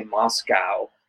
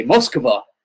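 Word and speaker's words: Moskva (Москва): Because in U.S. we say Moscow, cow, and that's so far away from how they say it. I should say Moskva.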